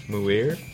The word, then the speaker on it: Muir: M-U-I-R. Muir? – Yeah, Muir.